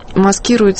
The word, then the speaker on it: маскирует: Он находился в настоящей клинической депрессии, а клиническая депрессия часто маскирует себя алкоголизмом. Маскирует это, кстати, медицинское ударение.